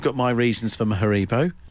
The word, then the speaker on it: Haribo: It's a European company. You will find it is actually pronounced Haribo, but less about that.